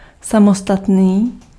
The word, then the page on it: separate: [ˈsɛp.ɹət]; /ˈsɛp.ə.ɹeɪt/; [ˈsɛp.ə.ɹeɪt]; /ˈsɛp(ə)ɹət/; /ˈsɛp.ə.ɹɪt/; /ˈsɛp.ɹɪt/